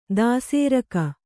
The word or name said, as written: ದಾಸೇರಕ